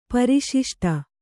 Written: ಪರಿಶಿಷ್ಟ

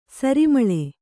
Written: ಸರಿಮಳೆ

